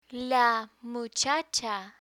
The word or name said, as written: la muchacha